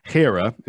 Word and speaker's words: Hera: First of all, Hera is how you pronounce it.